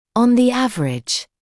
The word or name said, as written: on the average